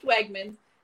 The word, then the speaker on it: Wegmans: is Wegmans, pronounced Wegmans.